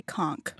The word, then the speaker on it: conch: In the Bahamas, they say conch.